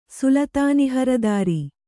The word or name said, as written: ಸುಲತಾನಿಹರದಾರಿ